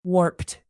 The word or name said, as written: warped